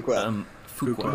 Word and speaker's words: Fuqua: Fuqua. We said it wrong for the whole series, so we're correcting it every time now.